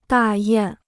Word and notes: 大雁: dà yàn

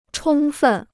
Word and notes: 充分: chōng fèn

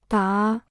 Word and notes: 答: dá

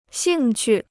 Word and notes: 兴趣: xìng qù

